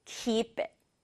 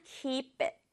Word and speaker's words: keep it: You can keep it. You can keep it. 주의할 점은 친구들, keep 다음에 it 있잖아요. 따로 떼지 말고, it은 강조받지 않으니까 keep it. Keep it. 이렇게 발음하면 더 자연스럽다는 거 한 번만 더 해볼게요.